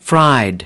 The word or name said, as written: fried